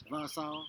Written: vasau

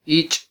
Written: ich

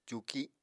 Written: cuuki

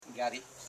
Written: ja.ri

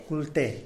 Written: kulte

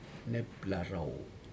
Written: neplaro

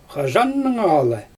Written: xaʒannaŋala

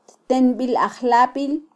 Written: ttenbilajlabil

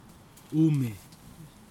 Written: ume

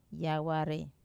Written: aware

